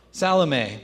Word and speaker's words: Salome: I'm not sure exactly how to pronounce Salome. Salome, there we go.